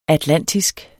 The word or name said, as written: atlantisk